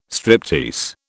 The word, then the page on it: striptease: British English